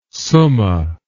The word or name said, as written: summa